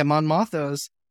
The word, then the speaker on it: Mon Mothma: And then you go to Mothma's, if I said her name correctly. Yeah, Mon Mothma's, and she's in the high rise.